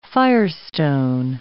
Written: firestone

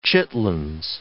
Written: chitlins

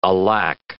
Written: alack

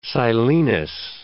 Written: silenus

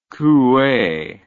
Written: Coue

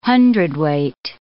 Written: hundredweight

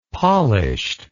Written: polished

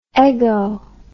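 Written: eggplant

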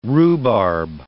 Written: rhubarb

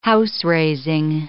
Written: house-raising

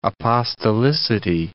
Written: apostolicity

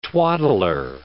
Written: twaddler

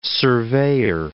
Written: surveyor

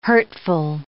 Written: hurtful

